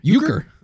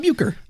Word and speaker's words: Uecker: The hotel maitre d', Carl Uecker. – Uecker. – Oh, jeez. – Like Bob Uecker.